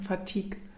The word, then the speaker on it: Fatigue: Du musst ein bisschen weicher aussprechen. Fatigue.